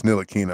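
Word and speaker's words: Ntilikina: My guy, Frank Nikotina. It's Nilakina. I'm going to stop mispronouncing his name, but Nikotina just sounds so much better.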